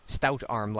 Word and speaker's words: Stoutarm: Stoutarm, like the beer, and then an arm. – I don't know what the beer is. – Stout? – Stout? S-T-O-U-T-A-R-M. – Brian? Brain or Brian? – Brian. B-R-I-O-N. – B-R-I-O-N.